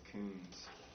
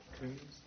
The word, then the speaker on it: Kunz: How do we say that? K-U-N-Z? – I think it's Coombs. – Coombs? – Yeah. – I think that's right. Yeah.